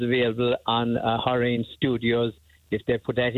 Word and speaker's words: Horion: Horrain Studios. – Spell that for us. – H-O-R-I-O-N. – Horrain Studios. Okay. – Yes, correct, yeah.